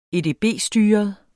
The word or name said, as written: edb-styret